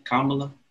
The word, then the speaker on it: Kamala: Kamala.